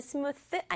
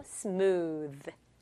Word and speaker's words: smooth: 그런데 진짜 영어 발음은 스무스 아니고 smooth. smooth. 이렇게 마셨을 때 매끄럽게 넘어가는 우리 마시는 음료 중에 스무디도 있죠. 오오 만나서 우 그리고 TH 만나서 으 소리 나요. 으 혀가 간질간질해야 돼요.